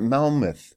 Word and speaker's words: Monmouth: Monmouth.